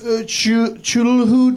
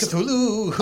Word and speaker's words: Cthulhu: Chulhu dice? – Cthulhu.